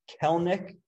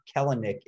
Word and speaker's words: Kelenic: The second piece of news that we want to talk about is Mariners top prospect, Jared Kelnick, which I have learned that I was pronouncing wrong the whole time. It is not Kelenic. It is Kelnick, according to a tweet that I saw earlier.